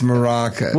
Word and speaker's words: maracas: I'm almost positive it's maracas. – What was I calling him? – Caracas. – Caracas? – Which may be a mispronunciation of curacao, the liqueur. The liqueur. – I was pronouncing Caracas.